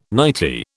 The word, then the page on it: nightie: British English